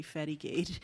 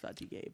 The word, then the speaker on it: fatigué: Who told her that he had had a long day and he was feeling very fatty gait. He may have been like French Canadian or something, in which case it would actually be pronounced fatigué, but still fatigué is hilarious because that's what kind of podcast this is.